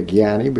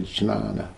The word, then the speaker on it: Jnana: We say Jnani because that's a kind of a Bengali or Hindi pronunciation, but if you really want to say Sanskrit, you say Jnana.